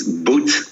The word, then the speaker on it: Boot: which is Boot Coffee. That's how the Americans pronounce my name. So from a boat, I became a shoe, basically. From a boat to a boot.